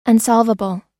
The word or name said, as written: unsolvable